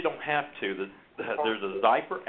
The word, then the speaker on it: Xypper: And it's called Zyper, and it's spelled XYPPER, Zyper.